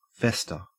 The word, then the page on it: fester: Australian English